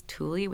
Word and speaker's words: Thule: So it looks like Thule, but it's Tule, which I think is a Danish word, comes from Ultimate Thule, which is a place in Greenland.